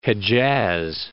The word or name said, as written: Hejaz